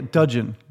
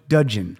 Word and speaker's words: Dudgeon: Dun- oh yeah, Dudgeon. – Dudgeon. Yep, that's how you say it.